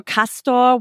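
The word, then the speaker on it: Castore: I think, is it Castoro or Castor? What is the actual pronunciation? – At the season opening party, I talked to Donato and he said it's Castor.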